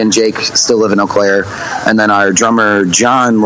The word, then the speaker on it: Eau Claire: It's Eau Claire.